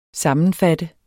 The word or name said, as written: sammenfatte